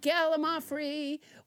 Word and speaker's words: gallimaufry: Let's never forget gallimaufry, which I pronounced wrong until nobody, James Hyder, corrected me.